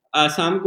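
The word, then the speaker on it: असम (অসম): आसाम मतलब आसाम को लोग थोड़ा वहाँ पे लोग क्या आ को ओ बोलते हैं ओ का प्रोनाउंसिएशन आता है तो असम बोलते हैं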